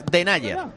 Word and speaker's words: Denayer: No, Denayer no, que lo hemos mirado antes. Denayer. – Denayer, correcto.